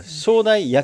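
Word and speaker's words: 焼台: あ、もちろん、はい、今おっしゃったのが正台、焼き台と書いて正台っていうんですけれども、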